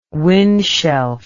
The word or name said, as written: windshelf